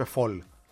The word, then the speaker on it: Fall: Μάλιστα, ο Άταμαν τον είπε Φολ, τον Φαλ. – Α, Φολ. – Φολ, ναι. Στην Αμερική το εξής, το λένε πολλές φορές και Φολ, γιατί έτσι προφέρεται το ρήμα fall.